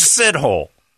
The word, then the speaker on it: Sithole: When we both know it's sit-hole. From here on, you're in America. S-I-T-H-O-L-E is sit-hole.